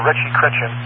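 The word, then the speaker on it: Ritchie–Chrétien: Because Hubble is not a simple Schmidt-Cassegrain, it's an RC, Ritchie Chrétien is the way they pronounce it.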